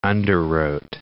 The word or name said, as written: underwrote